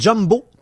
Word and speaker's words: Jumbo: Oui, c'est Jumbo. Tout simplement. Alors, exactement comme le mot Jumbo s'écrit J-U-M-B-O.